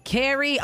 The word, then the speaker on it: Keri: Keri.